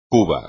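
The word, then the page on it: cuba: español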